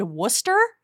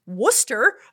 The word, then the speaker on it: Worcester: A city spelled Worcester that's pronounced Worcester. – I mean, if you want to be really technical, it's pronounced Worcester. – Yeah. – You don't need those R's in there.